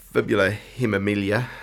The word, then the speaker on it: fibula hemimelia: I mean, you, fibula hemimelia, have I said that correctly? – Mm-hmm.